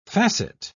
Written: facet

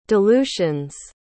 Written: dilutions

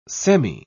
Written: semi

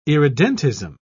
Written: irredentism

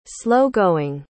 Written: slow-going

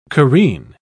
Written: careen

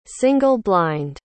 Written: single-blind

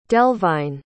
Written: delvine

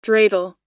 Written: dreidel

